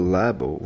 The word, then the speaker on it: Labo: I just want to mention first off that I heard yesterday that the reason they called it Nintendo Labo was because lab in Japan is pronounced Labo, and that way it's easier for them to call it Labo everywhere.